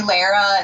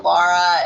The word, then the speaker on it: Lara: And it was this kind of name that I hated growing up with because everyone would always pronounce it wrong. And we had a Lara who was a couple years older than me in school. So all the teachers called me Lara instead of Lara.